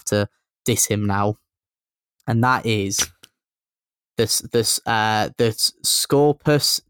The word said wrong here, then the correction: Scorpius: Disintegrator. Is it pronounced like that? I just call it the Scorpius. – Toaster. – Yeah, the Scorpius, the artillery piece.